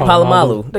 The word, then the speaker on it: Polamalu: Polamalu.